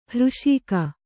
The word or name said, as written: ಹೃಷೀಕ